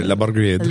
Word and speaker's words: brigade: You got to say it like... – Yeah, la brigade. – Like your French.